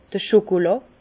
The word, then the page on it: d'r Chokolà: Alsatian; Bas-Rhin; Herrlisheim